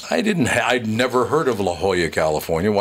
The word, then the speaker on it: La Jolla: But I was lucky because Charlie Bush, just after I said it's actually pronounced La Jolla. So I went back on the air and I said, Charlie Bush said it's La Jolla.